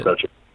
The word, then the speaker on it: Croce: Croce.